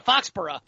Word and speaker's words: Foxborough: Oh, they got to go into Foxborough. – No, it's Fox B-O-R-O-U-G-H.